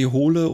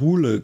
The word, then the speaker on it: Hole: mit seiner Harry Hole oder äh, Hule, glaube ich, wird es ja ausgesprochen, ähm, Reihe ähm, total mag.